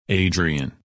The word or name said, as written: Adrian